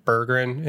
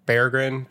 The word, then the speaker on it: Berggren: So, yeah, we're going to have to retrain our brains and stop saying Berggren and start saying Berggren because, one, that is the pronunciation, according to several people, and two, Lars is going to beat us up if we don't say it correctly.